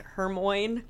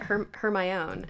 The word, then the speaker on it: Hermione: It's like in Harry Potter when everyone pronounced Hermione's name, like, Hermoine. I think there's a whole blog on ways people pronounced Hermione's name. – I did it Hermione until... – Actually, that's kind of pretty. I always said Hermoine, which is the ugliest sounding name. In the history of names.